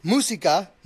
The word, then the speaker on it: Música: I go by música, which is music in Spanish. Most people just call me Music because they cannot pronounce it correctly.